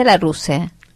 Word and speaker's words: Larousse: Porque es en francés, Larousse. No sé cómo, no sé cómo se pronuncia. – Yo le decía Larousse.